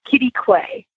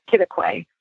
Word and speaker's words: Quitaque: Just spelled Q-U-I-T-A-Q-U-E. – Wow, that's a lot of letters. – And it's like there's a sign that says, don't know how to pronounce it, you call it Kittaquay. It's Kittaquay. And it's the town where Caprock Canyon State Park is.